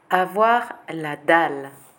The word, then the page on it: Avoir la dalle: French